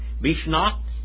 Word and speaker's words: Bishnat: Bishmat. Bishnat. I'll get it right in a minute. Bishnat. That's how you say it.